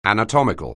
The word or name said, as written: anatomical